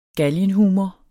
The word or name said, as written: galgenhumor